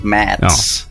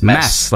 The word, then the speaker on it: Mads: Well, it's less cool when I tell you that the D is silent, I think. So it's not Mads. Oh.